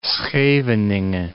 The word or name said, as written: Scheveningen